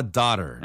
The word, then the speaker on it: daughter: You have a daughter. – 네, 발음 daughter 해도 되지만 미국식은요. – Daughter.